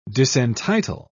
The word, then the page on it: disentitle: British English